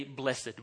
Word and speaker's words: blessed: So if it's used as an adjective describing something, we say blessed. We use the two-syllable version. But if we're talking about it as a verb in the past tense, it's blessed.